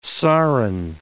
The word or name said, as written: sarin